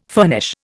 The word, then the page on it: furnish: Indian